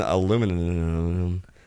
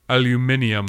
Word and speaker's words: aluminium: It's worse than aluminum. – Aluminium. – Right. – That's how the British say it.